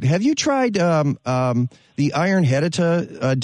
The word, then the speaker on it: HEDTA: And it's spelled H-E-D-T-A, but pronounced hedita. Go to a garden center and see if they have an iron hedita product.